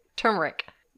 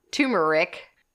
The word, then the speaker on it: turmeric: Turmeric. Not turmeric.